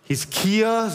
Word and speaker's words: Hiskia: Sein Urgroßvater war Hiskia. Hiskia, so muss man es richtig aussprechen.